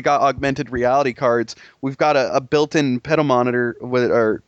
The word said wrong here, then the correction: pedometer: did I even say that right? Pedometer, pedometer, or pedometer. – Pedometer. – All right, there we go.